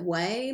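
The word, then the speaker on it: Ouai: But I think you pronounce it Way, maybe. It's O-U-A-I.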